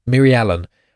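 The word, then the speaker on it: Mirialan: Now I will clarify, I find it very hard to pronounce this, so it's M-I-R-I-A-L-A-N. I think most people find it quite easy to pronounce, but clearly it's just me. Miri Allen.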